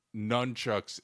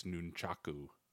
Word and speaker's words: nunchaku: But I do remember in the book, they specifically say that nunchucks is the wrong way to say it. It's nunchaku.